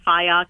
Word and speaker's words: Fyock: And my name is spelled with a C, Kathy with a C, and Fiok is F-Y-O-C-K.